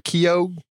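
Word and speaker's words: Keough: I never know how to say her name. Is it Keogh? – Yeah.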